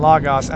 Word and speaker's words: logos: Yes, I know I just said logos and logos. I think it's logos is the proper pronunciation, the logos.